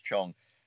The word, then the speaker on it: Zheng: Now, the person behind it, Livia Chung, even though it's spelled Z-H-E-N-G, it's pronounced Chung.